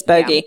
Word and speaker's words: bogey: Did I say that right? – Yeah, I think it is bogey. Yeah. – I want a bog. – It is. That's what I want to call it. – That's the bog. – How I read it, but I think it's bogey. – Yeah, it's in the it's in the pronunciation as bogey.